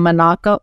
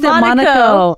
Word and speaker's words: Monaco: His father was a plastic surgeon from Monaco. Wait, I said it wrong last time. Is Monaco. – It Monaco? – Monaco. Okay. Monaco.